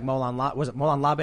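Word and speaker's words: Molon labe: We have people who have tons of guns, and we'll say something like Molan Labe, is that how you pronounce it? – Yep.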